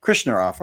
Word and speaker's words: Krishnarao: Krishnarao.